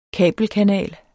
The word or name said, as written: kabelkanal